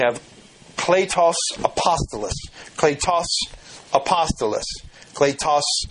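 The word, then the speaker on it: Kletos (κλητός): and that's wrong because the long sound is actually like our long A in the Greek, so it's really Kletos Apostolos.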